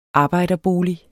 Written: arbejderbolig

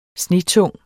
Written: snetung

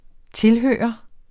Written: tilhørende

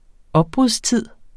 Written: opbrudstid

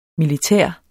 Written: militær

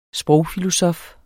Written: sprogfilosof

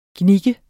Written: gnikke

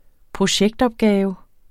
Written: projektopgave

